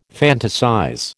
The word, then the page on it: fantasize: American English